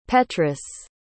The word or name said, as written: petrus